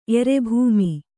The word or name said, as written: ಎರೆಭೂಮಿ